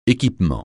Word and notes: équipement: français

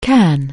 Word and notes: can: anglais

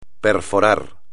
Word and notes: perforar: espagnol

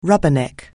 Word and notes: rubberneck: anglais

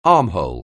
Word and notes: armhole: anglais

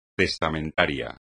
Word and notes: testamentario: espagnol

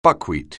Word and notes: buckwheat: anglais

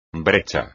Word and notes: brecha: espagnol

